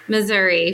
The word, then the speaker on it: Missouri: For an out-of-stater, is the correct pronunciation Missouri or Missouri? – Missouri, for sure.